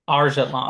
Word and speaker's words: Argetlam: Argetlam.